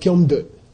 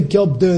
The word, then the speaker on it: kyom de: It's pronounced kyom de. Say kyom de. If you say kyop de in the monastery, they'll look at you like, what are you saying?